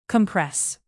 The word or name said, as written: compress